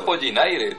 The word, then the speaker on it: Apollinaire: Es que esto está fatal, porque suena fatal, Apollinado. – Es que es Apollinaire, tío.